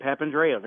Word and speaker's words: Papandrea: How am I doing pronouncing your last name? – Yeah, that's right, Papandreou. Very good.